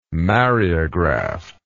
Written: mareograph

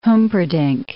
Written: Humperdinck

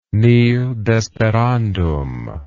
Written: nil desperandum

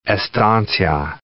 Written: estancia

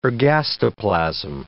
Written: ergastoplasm

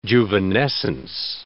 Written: juvenescence

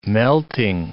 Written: melting

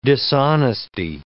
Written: dishonesty

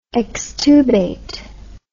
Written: extubate

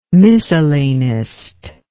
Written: miscellanist

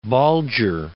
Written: Bolger

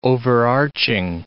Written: overarching

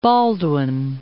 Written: Baldwin Park

